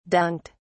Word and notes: dunked: British English

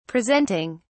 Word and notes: presenting: British English

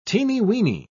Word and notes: teeny-weeny: British English

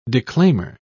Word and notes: declaimer: British English

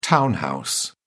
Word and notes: town house: British English

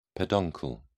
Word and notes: peduncle: British English

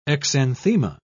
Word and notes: exanthema: British English

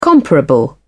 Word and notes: comparable: British English

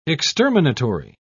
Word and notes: exterminatory: British English

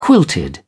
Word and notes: quilted: British English

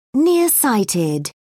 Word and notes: nearsighted: British English

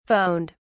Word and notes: phoned: British English

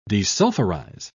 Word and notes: desulphurize: British English

